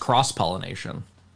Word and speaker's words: cross-pollination: It's pronounced cross-pollination. – Oh, sorry. okay. I'll go with you on that. I believe you. – He says it in the song.